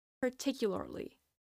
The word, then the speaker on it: particularly: A lot of people, since it's a hard word to pronounce per se, what they do is they just drop out the first R sound, and instead of saying particularly, they say particularly.